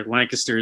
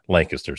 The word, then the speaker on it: Lancaster: So I'm impressed because a lot of people that, you know, they butcher that all the time. So you learn pretty quickly living around here. Lancaster is the way to pronounce it. So there is, it's pronounced the same way over by you? – It is.